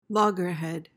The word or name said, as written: loggerhead